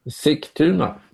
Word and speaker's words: Sigtuna: Sigtuna. – Sigtuna.